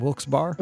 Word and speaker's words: Wilkes-Barre: He's in Wilkes Barre. Or Scranton, Pennsylvania. Did I say that right? Wilkes-Barre? – Wilkes-Barre. – Well, they need a Y on the end of that thing.